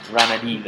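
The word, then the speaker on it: Ranadivé: It's got one of those things on the E at the end, one of those little tilde things. Ranadive.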